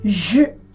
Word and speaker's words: je: うんジュス u の発音はジュ、私はというね、えー、自分を表現する一人称なんですけど、このジュっていう音、日本語にも英語にもおそらくないんじゃないかなと思います。